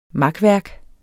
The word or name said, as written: makværk